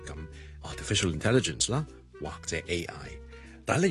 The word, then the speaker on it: artificial intelligence: 所以咧，artificial 咧其实后面有个 ism 嘅，所以 artificial 你咁楞埋落去，楞埋落去 intelligence 咧，intelligence 系 in 开头啦，vowel 开头，所以我通常会连埋前面嗰个音个 ism，所以我读成咧 artificial intelligence l i n 咁啊，所以 artificial intelligence。